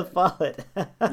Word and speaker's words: LaFollette: Wait, did I say it wrong? LaFollette, right? – LaFollette. Yeah. – LaFollette.